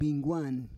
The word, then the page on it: bingguan: Roon